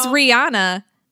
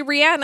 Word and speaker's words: Rihanna: That's how it's pronounced. Everybody says Rihanna, and it's actually Rihanna, and I try to respect that, but I'm not a jerk, and I don't correct everybody else. I just say it myself, okay? – Wow, thank you so much.